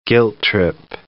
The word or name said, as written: guilt-trip